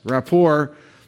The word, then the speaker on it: rapport: Yes, rapport, relationship with God, or one word, rapport. The T is silent.